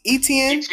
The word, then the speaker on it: Etienne: Travis, I always can not pronounce his name correct. It's Etienne. Travis Etienne, 205 rushing yards and 3 touchdowns.